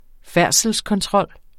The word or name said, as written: færdselskontrol